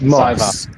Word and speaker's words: Moss: It's pronounced Moss. M-O-S-S.